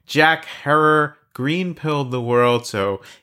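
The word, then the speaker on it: Herer: If you're thinking we must be talking with the man Jack Herrer, rhymes with terror, the Hemperer himself, I've got some unfortunate news for you, which is that he passed away quite a few years ago.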